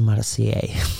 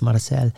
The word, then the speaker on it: Marseille: So, from from Portugal, we flew into Marseille uh, before I pronounce it as Marseille. so it's Marseille.